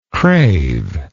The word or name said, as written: craver